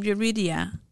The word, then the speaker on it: Yurida: And then even with my name, Yurida, and it's like, how can I?